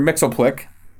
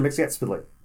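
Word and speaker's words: Mxyzptlk: and corrects him on the pronunciation of his name, and says that it is saying these four words is how you say his name, and it's Mr. Mix, Yes, Spit, Lick.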